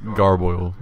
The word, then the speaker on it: garboil: The word of the day was garboil, which is... – Which rhymes with turmoil and means about the same thing.